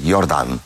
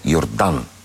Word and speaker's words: Йордан: Но ето тази връзка, те следят, те се интересуват и се гордеят, между другото, и дори Йордан непрекъснато коригира, защото те му вика Йордан. – Да. – Той вика, не, Йордан. Ударението е...